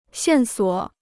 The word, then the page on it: 线索: xiàn suǒ